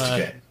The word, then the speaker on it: Takei: I think it's actually pronounced Takei, not Takei. – It is Takei. – Right, Takei.